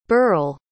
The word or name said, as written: burrel